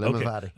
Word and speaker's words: Limavady: and it's called Limavady. Did I get it? Did I pronounce it right? – Perfect. Limavady.